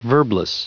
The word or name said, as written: verbless